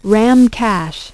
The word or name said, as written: RAM cache